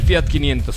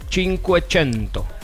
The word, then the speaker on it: Cinquecento: Pero fíjate, no se dice Fiat 500, se dice Cinquecento. – Cinquecento. – O sea, 500 en italiano, Cinquecento, Fiat Cinquecento.